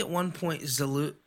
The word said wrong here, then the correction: Zaluna: is it Zalula? – Zaluna. – Zaluna, that's how you pronounce it.